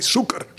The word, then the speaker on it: sûker: dat die even zei van, nee, zoeker, zoeker. Ja. Even goed uitspreken.